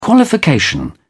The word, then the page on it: qualification: British English